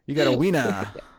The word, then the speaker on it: Weena: But there is definitely a couple of moments with this love interest later on, this Weena character, which, by the way, Weena, what a stupid name. That's spelled, for the record, W-E-E-N-A, Weena.